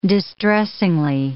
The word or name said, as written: distressing